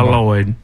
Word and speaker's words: Lloyd: And by the way, when you spell that, it's two L's. – Two. Okay. – It goes by Lloyd. – Got it. – First L's silent.